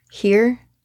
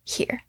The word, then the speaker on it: hir: So Z, Zim, it's Z here. So it's spelled H-I-R, but you would not pronounce it like her. It's here.